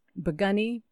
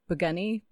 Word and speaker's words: Pikuni: And no, they're fascinating, and it is Biguni, but it's spelled, as most people know, P-I-K-U-N-I. So even though it is pronounced the Biguni Bigfoot Project, But Pikuni is spelled P-I-K-U-N-I.